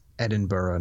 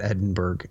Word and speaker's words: Edinburgh: All right, 1996, Renton, deeply immersed in the Edinburgh drug scene, tries to clean up and get out, despite the allure of the drugs and influence of friends. – It's Edinburgh, not Edinburgh.